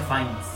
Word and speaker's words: Fiennes: dice que se pronuncia Fiennes. – Ah, sí. – Así más. – Fine, Ralph fine, fine.